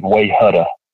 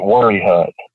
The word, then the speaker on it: Wayehutta: But at the same time, there are things like there's a place here that it's spelled W-A-Y-E-H-U-T-T-A, which to anybody else would look like Wayhutta. But we pronounce it Worry Hut.